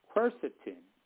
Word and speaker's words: quercetin: Another good one is quercetin. I'm going to spell that for you. Q. U-E-R-C-I-T as in Thomas, I-N, quercetin.